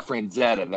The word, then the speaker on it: Frazetta: Now, one of my favorite artists, uh, I think his name Frank Franzetti, I think is how you pronounce it. Oh, he did a lot. Frazetta. – Yeah, Frazetta. That's it.